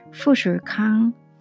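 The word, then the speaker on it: 푸슐캉 (富士康): 이 팍스칸은 좀 영어 발음이죠. 근데 중국어로는 푸슐캉 푸슐캉이라고 하죠.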